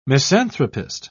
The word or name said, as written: misanthropist